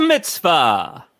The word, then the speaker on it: mitzvah: It's a mitzvah. – Mm. – That's how you pronounce it.